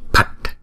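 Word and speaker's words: Phat: Nào, chữ P phở đứng một mình chúng ta đọc là pờ, mà đứng chung với chữ H chúng ta đọc là pờ, pờ, pờ. Không, chữ pờ nhưng mà nó nhiều hơi một chút, chúng ta đưa nhiều hơi ra pờ, phạch, phạch. Không, chữ T có dấu nặng thì chúng ta phải đọc là tờ, tờ, cho nên này là phạch, phạch.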